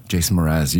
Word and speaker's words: Jason Mraz: It just kind of sounds like Jason Mraz. – Jason Mraz.